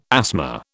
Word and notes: asthma: British English

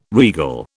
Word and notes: regal: British English

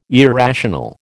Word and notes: irrational: American English

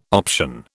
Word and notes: option: British English

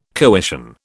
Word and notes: coition: British English

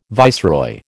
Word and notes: viceroy: American English

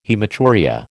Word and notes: haematuria: American English

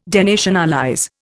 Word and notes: denationalize: Indian

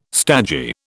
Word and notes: stagey: British English

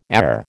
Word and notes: air: American English

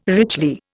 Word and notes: richly: Indian

